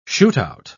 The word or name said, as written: shootout